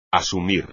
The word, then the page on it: asumir: espagnol